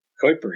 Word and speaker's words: Kuiper: Kuiper. He's a Dutch-American astronomer. And apparently in the Netherlands, that's how you say the word, Kuiper.